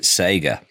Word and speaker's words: Sega: I'm going to bring a third pronunciation into this, because in the UK we pronounced it Sega.